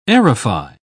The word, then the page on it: aerify: British English